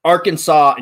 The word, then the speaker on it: Arkansas: You know why Arkansas and Kansas are pronounced differently, Kieran? – Why? – Arkansas is a French word for a Native American tribe that was found there. Kansas is an English word for a Native American tribe in Kansas. Hmm. So that's why they're pronounced differently. There's your fun fact for the day.